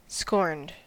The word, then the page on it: scorned: American English